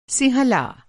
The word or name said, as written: सिंहला